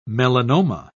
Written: melanoma

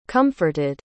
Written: comforted

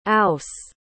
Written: ouse

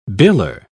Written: biller

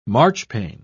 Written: marchpane

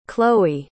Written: cloe

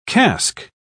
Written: cask

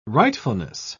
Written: rightfulness

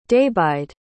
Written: daibheid

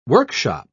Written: workshop

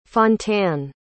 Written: fontanne

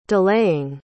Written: delaying

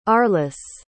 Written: arlyss

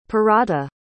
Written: perahta

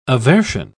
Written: aversion

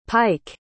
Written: pyke

